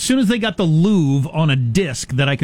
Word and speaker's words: Louvre: Louvre and look at the paintings, it'd be as good as being there. – I'm pretty sure it's pronounced Louvre.